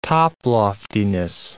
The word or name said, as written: toploftiness